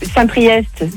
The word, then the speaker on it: Saint-Priest: T'as Saint-Priest, c'est EST, tu le prononces.